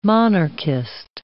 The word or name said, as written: monarchist